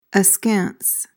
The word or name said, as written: askance